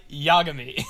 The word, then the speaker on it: Yagami: He kept saying Light Yagami. It's Light Yagami.